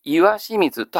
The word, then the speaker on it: 石清水: 42、岩清水、石清水と書いて岩清水と発音します。これは岩清水八幡宮という神社のある場所なんですけども、その岩清水八幡宮にかけて、岩字、つまり濁点をつけると、それが岩字になるというような書き言葉です。なかなか何と岩清水。